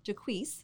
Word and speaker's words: Jaques: I'm so glad to have John Jaques. Did I say that correctly? – Pretty close. – Go ahead and say it the right way then. – Jaques. – Jaques. There we go.